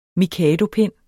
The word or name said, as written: mikadopind